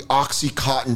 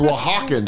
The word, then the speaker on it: Oaxacan: I want the Oxycontin chocolate mousse cake. And I went, I don't think that's how you say it. And I think it's Oaxacan chocolate mousse.